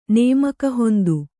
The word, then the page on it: ನೇಮಕಹೊಂದು: Kannada